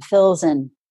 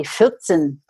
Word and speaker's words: Filzen: F as in Fred, I-L-Z as in zebra, E-N, Filzen. It's a German last name. I don't pronounce it in the German way. It would be Filzen, but you know, no one wants to spell that. So anyway, it's Filzen, like fills in the blanks.